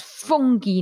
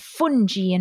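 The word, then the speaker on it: funghi: And now I know what, oh, it's fungi, not fungi. And there's a H in there and it does this.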